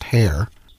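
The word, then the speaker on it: Haigha: Yeah, because like in the book, it has that spelling for the hare, but it says, like, specifically says that it's pronounced hare.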